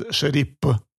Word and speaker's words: Sharif: But in Chechen and in Dagestani languages, there is no sound F. – Huh. – So that's why it's... – Sharip. – Yes. Sharif. Pronounced is Sharip.